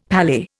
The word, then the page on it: palais: Indian